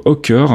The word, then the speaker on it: Ochre: Et puis on continue dans la musique avec un nouvel album pour Hawker. Euh, Alors ça s'écrit O C H R E pour les anglophobes.